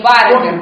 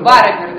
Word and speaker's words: барабир: Ну, извините, ударение барабир. Помню, давно не говорила вслух. Барабир, да, барабир. Не барабир, а барабир, да.